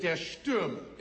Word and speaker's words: Der Stürmer: Der Stürmer.